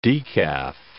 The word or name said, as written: decaf